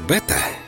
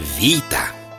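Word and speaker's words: вита (βήτα): Вторая буква греческого алфавита, думаете, бета? Нет, вита. Сами греки произносят ее именно так, а не иначе.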